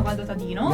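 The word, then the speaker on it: Gualdo: Viene da Guado, ho detto bene? – Sì, da Guado Tadino.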